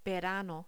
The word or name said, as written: verano